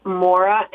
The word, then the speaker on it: Maura: My name is Maura, M-A-U-R-A, Geist, G-E-I-S-T, and it's .com.